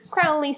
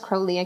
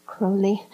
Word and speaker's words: Crowley: Like, I think that it'd be interesting to dive deeper into the religion, like over on the Aleister Crowley side. Yeah. And see whatever's there. And I know it's Crowley. I keep saying it wrong. Sorry. Crowley.